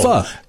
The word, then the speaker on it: pho: It's pronounced pho. – Pho? – Pho. – Like something we shouldn't say on the radio. – Like P-H-U. Pho. – Yeah. – Pho. Like but fun, but without the N.